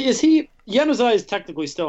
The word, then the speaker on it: Januzaj: Yeah, 2 goals in injury time in the first half, both on assists by Adnan Januzaj. – That's a soft J. You got to learn when to soft J and when to hard J.